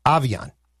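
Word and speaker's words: Aviante: Pronunciation per the Vikings, Avian, A H capital V E E hyphen O N Okay.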